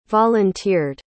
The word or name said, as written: volunteered